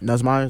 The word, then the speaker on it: Nussmeier: Nussmeier, is that how you say it? – Yeah. Mm-hmm.